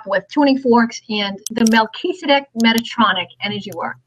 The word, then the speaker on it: Melchizedek: Melchizedek. So it's Mel, like Mel Gibson. – Okay. – Keys, like a set of keys. So it's Mel Keys. A deck, like a deck of cards. – Melchizedek.